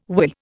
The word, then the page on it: whirr: Indian